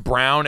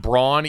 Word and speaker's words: Braun: it is pronounced Brown and not Braun, even though it is spelled right. Braun.